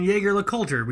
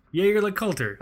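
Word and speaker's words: Jaeger-LeCoultre: It's fucking Jaeger LeCoultre. We just talked about this. Jaeger. – We get made fun of. – Jaeger LeCoultre.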